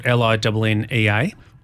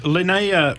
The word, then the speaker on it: Linnaea: So it'll be so the winery is spelled Linnaea. – Almost, it's Linnaea.